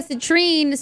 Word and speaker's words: citrine: Correct me if I'm saying it wrong. Citrine. Un amarillito. Citrine, ¿va? Citrine. There you go.